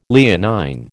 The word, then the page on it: Leonine: American English